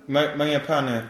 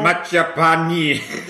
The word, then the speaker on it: Mangiapane: Mangiapane? – Mangiapane. Ja, das ist so, sprichst wie ein Deutscher aus, wie man so Prosciutto. Ich höre einmal Prosciutto. – Mangiapane.